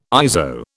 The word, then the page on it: ISO: British English